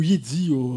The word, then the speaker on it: Yahtzee: Une rubrique qui s'intitule Yahtzee. – Ou Yahtzee, selon la prononciation anglo-saxon.